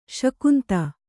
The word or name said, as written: ಸುಗಂಧ